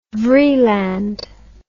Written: vreeland

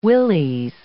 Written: willies

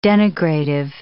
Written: denigrative

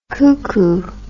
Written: coucou

